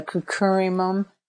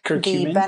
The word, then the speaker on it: curcumin: curcumin. I'm not exactly sure how it's pronounced, but curcumin. – Curcumin, exactly.